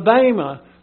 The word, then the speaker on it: bema: The bema, that's how you pronounce it.